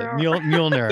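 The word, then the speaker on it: Mjölnir: Mjolnir. – Very close. Mjolnir.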